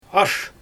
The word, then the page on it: ash: Xyzyl